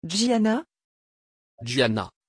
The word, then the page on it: giana: French